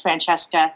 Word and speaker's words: Francesca: Francesca, am I pronouncing your name right? – Yes, you are. Sorry, I just couldn't get off mute there. Yeah, it's Francesca.